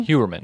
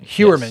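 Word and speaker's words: Heuermann: Did they say Huerman? – Huerman. – Huerman, yes. Yeah. Rex Huerman. I mispronounced his name the first few times I was reading it, so we had to watch them and kind of talk about it.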